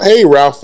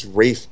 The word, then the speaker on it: Ralph: Ralph Fiennes. No, it's Rafe Fiennes. Really? It's Rafe? – Pretty sure that's Ralph. – No, spelled Ralph, pronounced Rafe.